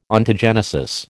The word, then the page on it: Ontogenesis: American English